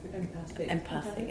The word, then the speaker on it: empathic: and there's one clip which is called the emphatic, how does it word? – Empathic. – I always say it wrong.